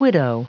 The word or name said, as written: widow